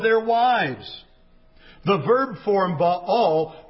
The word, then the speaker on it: Baal: In English, we say Baal. B-A-A-L. Baal. A Canaanite god. It should be pronounced Baal.